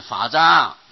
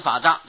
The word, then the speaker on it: 法藏: 若持八万四千法章，这句话就要连起来啊，不要念成若持八万四千法章，不能这样念。啊，若持八万四千法藏，这是连起来的啊。如果要受持佛所说的八万四千法藏，法藏有八万四千吗？